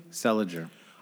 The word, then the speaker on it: Seliger: How do you pronounce his last name? – Seliger.